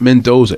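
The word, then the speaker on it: Mendonça: Pronounced Mendoza. It looks like Mendonca, but I heard him announce in some fights before. It's actually Mendoza or Mendoza.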